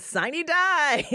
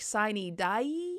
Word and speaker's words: sine die: Syllables in this, Lisa? Because I sometimes hear extra syllables like sine die. – Sine die. But, you know, we just heard from the audio there. That was a long die there.